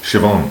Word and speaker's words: Siobhan: Siobhan.